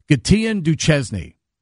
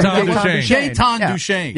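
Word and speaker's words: Gaétan Duchesne: Years and years ago, I'll never forget a news person pronounced this Capitals player's name, Gatien Duchesny. – Oh. – Anybody remember Jaetan Duchesne? – Yeah.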